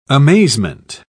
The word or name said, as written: amazement